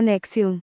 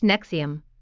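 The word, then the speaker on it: NXIVM: Chibn, pronunciado Nexium,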